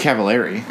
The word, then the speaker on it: Cavallari: I thought it was Cavallari. – It's like calamari with a K and an M or a B.